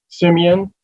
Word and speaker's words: Semien: So my name is Kenneth Simeon Senior, and that last name is spelled S-E-M-I-E-N.